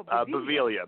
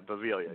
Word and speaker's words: Beviglia: Do that whole Beviglia. Beviglia.